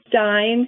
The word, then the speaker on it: Stine: Our firm is called Cohen Stein Kapoor. Now, my last name is spelled S-T-I-N-E. Cohen Stein Kapoor,